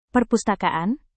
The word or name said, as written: perpustakaan